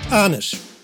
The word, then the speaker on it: Arnisch: This is Tom Arnisch of Arnisch Models from Germany speaking. And now you know how to pronounce my name. Arnisch. Simple like that.